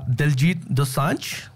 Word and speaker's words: Diljit Dosanjh: How do you say it? – Diljit Dosanjh. – You said Dijanasura. That's a totally different.